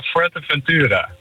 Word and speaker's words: Fuerteventura: Ja, Fuerteventura. – Ah, oké, okay. Fuerteventura.